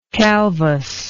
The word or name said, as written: calvous